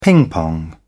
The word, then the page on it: ping pong: British English